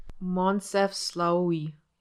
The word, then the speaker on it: Moncef Slaoui: Moncef Slaoui.